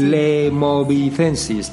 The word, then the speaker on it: Lemovicensis: Vía Lemovicensis, ¿está bien dicho? – Sí, sí. – Lemovicensis, también conocida como el Camino de Vézelay.